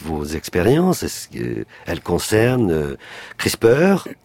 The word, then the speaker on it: CRISPR: On va dire CRISPR.